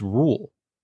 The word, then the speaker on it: Roald: We've got, Roald Dahl, which I just found out that you don't pronounce the D in that name. The guy that wrote Charlie and Chocolate Factory, it's Rule.